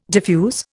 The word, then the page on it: diffuse: Indian